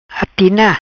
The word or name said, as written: Atina